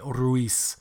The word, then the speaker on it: Ruiz: So I mean, you know, if you were to pronounce it, you know, with a Spanish accent, it'd be Ruiz, because the Z in Spanish is more of an S.